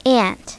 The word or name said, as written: aunt